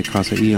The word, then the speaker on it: Kasai: DJ Zenobia from Congo did Tom and Jerry prior to this, the instrumental, and the Kasai, I believe now, I've been saying it wrong all along. I heard them say Kasai on this song.